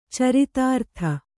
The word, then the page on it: ಚರಿತಾರ್ಥ: caritārtha